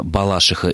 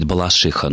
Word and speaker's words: Балашиха: во всех случаях ударение падает именно на этот суффикс, поэтому, думаю, и в топонимах Балашиха и Барвиха ударение должно быть точно таким же.